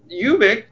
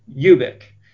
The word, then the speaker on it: Ubik: So there's a very famous interview that he did, that PKD did with Pacifica Radio, where he did address the pronunciation of Ubik, and it is, I believe, technically Ubik. And yeah. Anthony, you want to read the quote? – Ubik.